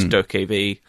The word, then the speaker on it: Dokkaebi: I think it's pronounced Dokkaebi. It's Dokkaebi.